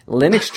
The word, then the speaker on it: Linux: Now, you are Linux Journal's, wow, I mispronounced Linux, Linux Journal's webmistress, and you're the resident Drupal nerd of Linux Journal? – That would be me, yeah.